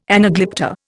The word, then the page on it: anaglypta: Indian